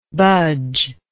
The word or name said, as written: budger